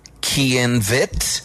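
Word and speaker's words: Chaim Witz: Pero ¿sabía usted que Gene Simmons nació en Haifa, Israel, y que su nombre oficial de bautizo es Kian Vitt, que se escribe Chaim Waits, pero se pronuncia Kian Vitt?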